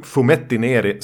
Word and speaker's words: Fumetti Neri: Eh, det finns alltså en genre som heter Fumetti Neri. Mm. Bara dubbelkolla uttalet där. Jo, Fumetti Neri som var italienska serietidningar som breakade tidigt 60-tal.